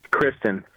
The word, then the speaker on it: Kristin: Kristen. – What, Bridget? – Kristen. – Oh. How do you spell it? K-R-I-S-T-I-N? – Yeah, that's her.